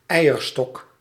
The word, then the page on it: eierstok: /ˈɛiərstɔk/